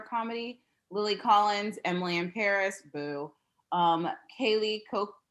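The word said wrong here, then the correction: Cuoco: I never can pronounce her name. – Coco.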